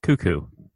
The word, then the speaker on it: Koekkoek: One more time. I need that name because that's an A-plus pronunciation. – Slater Cuckoo. – K-O-E-K-K-O-E-K.